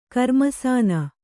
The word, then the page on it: ಕರ್ಮಸಾನ: karmasāna